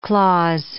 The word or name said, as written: clause